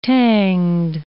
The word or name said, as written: tanged